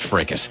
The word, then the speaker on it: fracas: So it is, in fact, pronounced fracas.